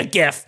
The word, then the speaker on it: GIF: GIF. And it is a hard G. – Hell yeah. – Not a soft G.